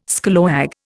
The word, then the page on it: scallawag: Indian